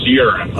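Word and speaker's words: Yuran: It's Y-U-R-A-N, but it's still pronounced urine.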